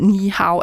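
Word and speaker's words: Ni Hao (你好): Min udtale af Ni Hao, er det helt galt? – Jeg synes, det er meget godt. Ni Hao.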